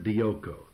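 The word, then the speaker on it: dioko (διώκω): Now, let me tell you, it is the Greek word dioko. That is spelled D-I-O-K-O. The first O there is omega, dioka. And that's the long O, D-I-O-K-O.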